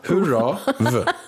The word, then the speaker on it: Hurrav: Alltså som hurra, fast det... alltså, hurra, v. Hurrav.